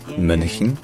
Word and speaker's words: Mönchen: Puis on a Chin. Alors là, c'est un peu plus compliqué. D'ailleurs, le CH ne se dit pas comme un CH français. Il se dit CH, c'est-à-dire un petit peu comme un CH, mais sur le palais, comme pour prononcer un IE. Donc ça devient CH. Ensuite, le N, il est prononcé suffisamment longtemps pour qu'on n'ait pas besoin de dire une vraie voyelle. Donc ce n'est pas CHEN, c'est CHIN, donc MÖNCHEN.